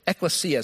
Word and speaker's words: ekklesia: There's a Greek word that's ekklesia is how it's pronounced, and that means church in Greek.